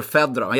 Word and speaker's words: Fedorov: Fjodor Fjodorov, ska man säga så om honom? Är det så man uttalar det? – Fjodor Fedorov. Jag säger alltid Fedorov om Sergej Fedorov.